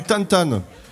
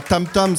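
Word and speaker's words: tantã: Là-bas, David, tout au bout, il joue du tantan. En fait, je le prononce pas bien, c'est pas tam-tam, c'est tantan, tantan.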